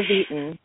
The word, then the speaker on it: Vieten: It's Cassandra Vieten.